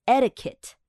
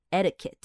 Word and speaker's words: etiquette: 那这个字是礼仪、礼节，呃，这个规范、规矩。那怎么念呢？很多人看到，因为它是 e t i q u e t t e，很多人可能会想要把那个 quit 念出来，很多人可能会念成 etiquette，but it's actually not. It's etiquette, etiquette.